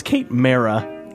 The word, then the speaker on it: Kate Mara: By the way, it's Kate Mara. All been saying that wrong.